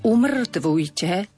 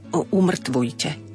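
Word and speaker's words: umŕtvujte: Slovo umrtvujte má dlžeň na r, aby nebolo umrtvujte. Umrtvujte a vyratúvame.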